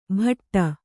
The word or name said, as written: ಭಾಟ್ಟ